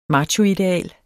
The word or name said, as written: machoideal